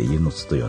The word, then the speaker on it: 温泉津: あとスキちゃんとこの近くで温泉津と書いて湯の津と読んだりね。